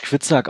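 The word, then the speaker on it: Hvitserk: Ivar, Quistek, Übbe. – Quitzak. – Was? – Quitzak. – Ja, der wird auf Deutsch Quitzak ausgesprochen. Wenn man mal rumguckt, wie das geschrieben wird, weiß ich nicht, ob das tatsächlich so ist.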